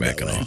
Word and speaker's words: Mackinac: It's Mackinac? – That's right. It's pronounced Mackinac.